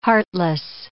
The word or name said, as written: heartless